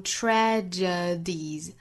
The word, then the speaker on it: tragedies: 而 tragedies, tragedy 这个词同样是需要格外注意的，有三个音节读成 tragedies, tragedies。